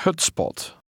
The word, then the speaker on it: hutspot: The S and P are pronounced separately. – Ik eet graag hutspot.